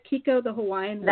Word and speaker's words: Kiko the Hawaiian Wave: Did I pronounce it right? Kiko the Hawaiian Wave. – That's right.